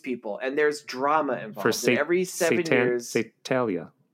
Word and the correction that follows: Saturnalia: What's how you say it? – Saturnalia. – Saturnalia.